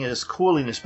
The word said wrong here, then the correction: Cool Lines: but it's cool lines.